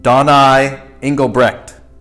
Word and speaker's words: Engelbrecht: Here it is, the one, the only, I'm going to make sure and say it right, Donai Engelbrecht.